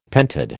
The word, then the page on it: pentad: American English